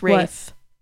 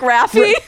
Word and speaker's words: Rafe: R-A-F-E-S-P-A-L-L. – Honey, that's Rafe. That's Rafe? – Like Raphael. – Okay. – Not like Raffy.